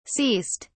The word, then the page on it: ceased: British English